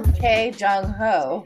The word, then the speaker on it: Choi Jung Ho: Sungho? – What? – Wait, how do I pronounce his name again? How do I say his name? – Oh my gosh, it's Choi Jung Ho.